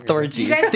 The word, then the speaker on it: Thorgy: Thorgy. Think of Orgy.